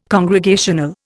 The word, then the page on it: congregational: Indian